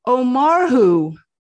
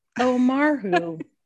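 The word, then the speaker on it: Ó Murchú: His last name is written O apostrophe M U R C H U, which is pronounced Omarhu. – Omarhu.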